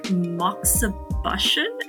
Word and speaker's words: moxibustion: I mean, acupuncture is only one method of solving so many issues, and you have so many other methods, like moxibustion. Am I saying that correctly? – You're saying it perfectly.